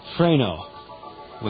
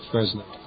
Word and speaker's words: Fresno: I often wondered if the best pronunciation for that word is Freno, without the S, but, you know... – Stick with Fresno.